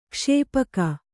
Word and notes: ಕ್ಷೇಪಕ: kṣēpaka

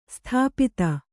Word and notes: ಸ್ಥಪತಿ: sthapati

